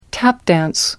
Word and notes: tap dance: British English

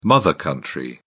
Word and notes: mother country: British English

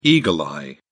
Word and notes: eagle-eye: British English

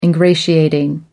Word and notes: ingratiating: American English

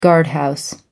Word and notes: guardhouse: American English

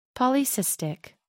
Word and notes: polycystic: American English

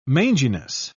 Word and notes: manginess: British English

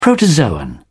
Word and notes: protozoan: British English

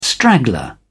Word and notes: straggler: British English

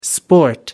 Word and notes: sport: American English

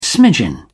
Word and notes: smidgen: British English

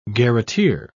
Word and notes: garreteer: British English

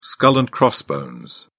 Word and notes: skull and crossbones: British English

